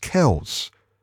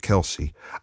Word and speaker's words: Kelce: There's on a screen next to me, it says that Travis, his surname Kelce, apparently it's pronounced Kells.